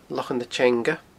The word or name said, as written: Lochan na Teanga